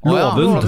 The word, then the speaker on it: Lovund: Altså, broren min bor på Låven, en liten øy ute i havgapet. – Gjør han det? – Rett ved Træna. – Ja, men uttales det Låven? – Låven. Und. Lovund.